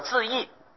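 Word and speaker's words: 智顗: 原来他的名字的法名叫做智凯啊，有人念作智义。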